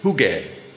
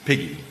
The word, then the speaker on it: hygge: I think I, I, I, spent a long time listening to how you pronounce this word, "hygge," right? – Hi. Apparently it's not meant to rhyme with piggy, piggy.